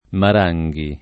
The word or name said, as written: Maranghi